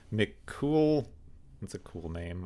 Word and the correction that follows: McCullough: I'm probably pronouncing it wrong. – McCullough. – McCullough.